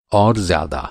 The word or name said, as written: ज़्यादा